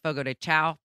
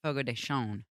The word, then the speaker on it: Fogo de Chão: Everybody calls it Fogo de Chão, but it's pronounced Fogo de Chão.